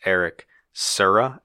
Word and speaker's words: Serra: I believe the composer, it's pronounced Eric Serra, S-E-R-R-A.